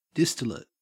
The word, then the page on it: distillate: Australian English